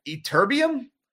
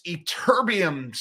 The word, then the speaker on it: Ytterbium: Ytterbium, along with related materials.